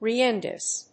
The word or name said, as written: reengages